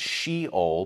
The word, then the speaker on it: Sheol: Now, the words the grave right there, that's the first appearance of the word Sha'ul, or we would pronounce it Sheol, but it's Sha'ul in Hebrew.